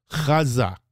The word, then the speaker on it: chazak: Yes, chazak, chazak, and that's with a chet. So you really got to get that ch in there.